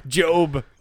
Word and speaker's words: Gob: Job. – But it's spelled G-O-B. It's just G-O-B.